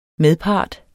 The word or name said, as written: medpart